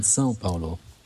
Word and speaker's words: São Paulo: ich richtig wäre eigentlich mit der schönen Cedilla über dem, über dem A in Sao Paulo. – Sao Ganz äh, Paulo. sanft nach, genau so nach vorne ausgesprochen.